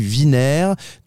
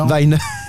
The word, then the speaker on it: VinR: Moi, je dis Viner. Toi, tu dis Weiner. Moi, je dis Weiner. – Weiner. Voilà. ok. Viner marche très bien aussi.